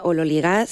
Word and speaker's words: ololigas: Teóricamente se pronuncia ololigas según la, bueno, pues la transcripción fonética del griego al español,